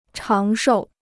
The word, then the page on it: 长寿: cháng shòu